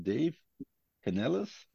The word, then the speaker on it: Dave Canales: Die Panthers haben Dave Canales, heißt er so, mhm.